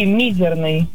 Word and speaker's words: мизерный: Нет, пожалуй, я воздержусь и свое мнение отстаю, что это будет слово «мизерный». Ударение на первый слог.